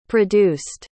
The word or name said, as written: produced